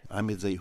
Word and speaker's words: Ahmed Zahir: Oh, how do you spell this name? – Z A H I R. Ahmed Zahir.